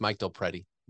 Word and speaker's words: DelPrete: how do you say it? – DelPrete. You're saying, now you're messing me up. It's Mike DelPrete.